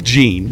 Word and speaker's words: Jean: First of all, it's Wyclef Jean. – It's Jean. – Okay, but Jean. – It's spelled J-E-A-N, and we're in... – Again, he's Haitian. They speak French.